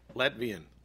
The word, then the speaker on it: Latvian: Latvina. Latvine? I don't know. – Latvian. – Latvian? – Latvian.